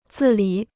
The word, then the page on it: 自理: zì lǐ